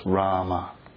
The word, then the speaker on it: Rama: Two syllables, Rama.